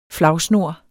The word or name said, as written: flagsnor